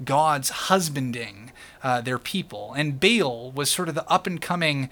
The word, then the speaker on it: Baal: Sometimes you'll hear this pronounced Baal. That's the proper Hebrew pronunciation, Baal, but most of us Westerners will say Baal.